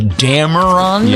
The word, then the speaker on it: Dameron: Mr. Poe, I think I'm pronouncing this correctly, Dameron? – Yeah.